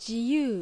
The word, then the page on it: Jiyuu: Japanese